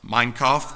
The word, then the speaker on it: Mein Kampf: Listen to what Adolf Hitler wrote I'm not going to pronounce it right, M-E-I-N-K-M-P. Mein Kampf, thank you.